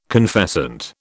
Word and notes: confessant: British English

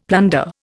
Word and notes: plunder: Indian

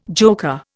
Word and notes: joker: Indian

